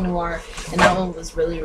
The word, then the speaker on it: Noir: N-O-I-R. – I don't know how to say it. – Oh, Noir. – Yeah, that's how you say it. – Yeah, it's Noir.